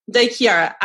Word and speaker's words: De Chiara: De Chiara.